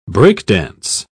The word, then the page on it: break-dance: British English